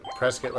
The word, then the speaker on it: Prescott: Prescott like biscuit.